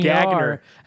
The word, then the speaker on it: Gagner: It's spelled G-A-G-N-E-R, Gagner. – And it's Gagner. – If he was from, Chicago, he'd say Sam Gagner. – Yeah, Boston, it'd be Gagner.